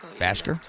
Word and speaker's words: Bhasker: The last name, let's spell it for you. B-H-A-S-K-E-R. Basker? – Oh, that guy. – You talking about Billy Craven? You talking about U-G-L-Y?